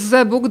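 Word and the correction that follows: Żebbuġ: Dobrze mówię, czy źle wymawiam? – Zebucz, Zebucz. – Zebucz, okej. Okay.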